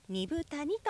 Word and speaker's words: 二風谷: 根健二さんが住んでいるアイヌの里平取町二部谷にやってきました自然豊かな場所にありまして緑の森に囲まれ猿川が流れる中アイヌの伝統が色濃く残る地域です 2 つの風の谷と書いて二部谷と読みます